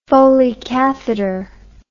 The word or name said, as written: Foley catheter